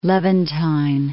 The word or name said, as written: Levantine